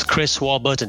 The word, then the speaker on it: Chris Warburton: And today I'm joined by Chris Warburton.